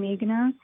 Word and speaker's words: Meghana: And the idea is that, like for me, having the name Meghana and growing up in America, it's often mispronounced.